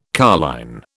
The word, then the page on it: carline: British English